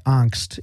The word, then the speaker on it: angst: And I was explaining to her my angst. And that is the correct way to say that word, by the way.